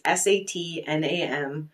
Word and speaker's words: Sat Nam: It's Satnam. It's pronounced Sat Nam.